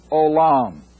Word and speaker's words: Olam: But in the Hebrew, that everlasting God is Elohim Olam, O-L-A-M, and it's pronounced O-L-A-W-M in the Hebrew.